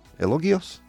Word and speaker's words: elogios: MrBeast construye 100 pozos en África y suscrita elogios y críticas. Elogios? Is that right? – Elogios.